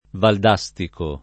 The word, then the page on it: Valdastico: Italian